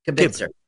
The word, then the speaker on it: Kibitzer: Kibitzer. Like kibbles and bits.